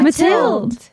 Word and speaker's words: Matilde: It's just Matilde, but Matilda's fine. – Matilde.